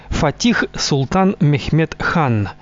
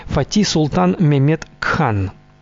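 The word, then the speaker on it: Fatih Sultan Mehmed Han: Его полный титул – Фатих Султан Мехмед Хан, или в турецком произношении – Фати Султан Мехмед Кхан.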